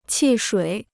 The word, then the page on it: 汽水: qì shuǐ